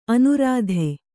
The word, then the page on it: ಅನೂರಾಧ: anūradha